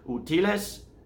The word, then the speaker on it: útiles: Útiles escolares. It's just fun to learn things, right? So útiles escolares. Emphasis on the T, right? But the actual emphasis in the first word, útiles, is U.